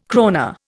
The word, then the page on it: krona: Indian